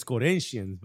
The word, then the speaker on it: Corinthians: It's Corinthians, by the way. – Mm-hmm. – Let's give them the respect they deserve for pronouncing it in a way you would have never expected. Shouts to the Portuguese.